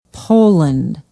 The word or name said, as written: poland